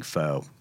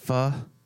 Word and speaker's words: pho: Just my wife loves pho, right? – Uh-huh. – And I feel criminal not saying pho for some reason. I hate that I have to say pho and say it right.